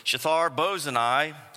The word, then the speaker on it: Shethar-Bozenai: At the same time, Tattenai, the governor of the province beyond the river, and Shethar-Bozenai, that's how you say it,